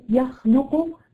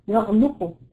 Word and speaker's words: يخلق: كلمه يخلق في بعض البلدان اللي بتنطق العاميه غاي، يغلقوا يغلقوا بدل يخلق يغلقوا.